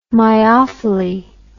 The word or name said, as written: myophily